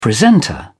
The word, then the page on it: presenter: British English